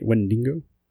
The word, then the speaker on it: Wendigo: Jersey Devil, Bigfoot, the Wendigo. Is that how you say it? Wendigo? – Wendigo.